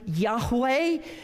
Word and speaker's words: Yahweh: We say Jehovah. There is no J in the Hebrew language. It's actually a Yah. Yeah, it would be Jehovah, but it's actually the proper way to pronounce it might be Yahweh, but there's different debates on that.